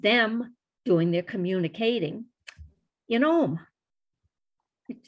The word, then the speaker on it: Ogham: One thing that I saw there I'd never seen before, never even heard of, was Ohm stones. Ohm is spelled O-G-H-A-M.